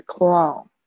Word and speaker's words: cologne: 首先第一个字是 clone，这个 G 呢不发音。